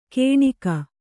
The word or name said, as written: ಗೇಣಿಗ